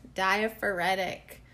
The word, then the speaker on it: diaphoretic: Today, we're going to get na-na-na-na-na-nerdy with the word diaphoretic. So, yeah, basically makes you sweat, right? Induces perspiration is what diaphoretic means. Am I saying it right? Let's check in with my co-host. – Diaphoretic.